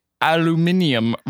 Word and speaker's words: aluminium: Brutal, as you would like to say, aluminium riffs, I would say. – Isn't it weird that, like, they pronounce it that way? – Yeah. – Aluminium. I feel like that takes a lot of effort.